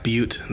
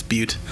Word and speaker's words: Butte: It's Black Butte. That's how you pronounce that word, B-U-T-T-E. It's Butte.